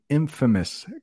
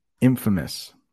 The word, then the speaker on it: infamous: If you look at that word right there, think of famous, well-known, but when we put in front of it, it means famous for a bad reason. And we change the pronunciation. Hello, everyone. The infamous guy here. Infamous.